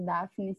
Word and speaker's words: Daphne: meu Instagram é Daphne Lopes com dois S. No caso, Daphne se escreve com D-A-P-H-N-E.